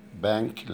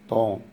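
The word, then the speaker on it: banc: ان بون ستيلا تنطق فيه، محدش بيقول عليها بون بانك، لا بون، والان طبعا نزال ف بتنطق كده ان بون.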